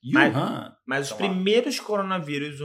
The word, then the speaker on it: Wuhan: Xuan. Xuan. Como, é, é como é que fala a cidade? É Wuhan. Não. Tá. Você escreve W-U-H-A-N.